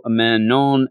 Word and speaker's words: Manon: We do start off, you know, in the whole Blackstone deal with Sebastian Shaw talking to Manon and Maxima. is what I have found out is the pronunciation.